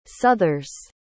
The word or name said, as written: southers